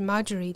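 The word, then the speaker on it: Margery: 那到了十五世纪，就其实他俩生命是有交集的，就是后来呃第二位这个十五世纪叫 Marjorie Camp 啊，我们叫他玛格丽好了，就虽然发音是 Marjorie，但是。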